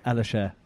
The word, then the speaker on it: Elisher: I think you're with the Tony, and I never know how to pronounce the surname. – Tony Elisher. – Elisher, right. Foundation. It is as it's written.